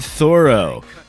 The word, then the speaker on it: Thoreau: In 1847, Henry David Thoreau leaves Walden.